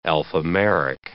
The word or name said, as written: alphameric